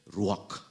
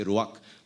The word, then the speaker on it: ruach: the CH is coming from the throat. You know, so it's hard for me. So I tend to say ruach, but it's like ruach, you know, something like that.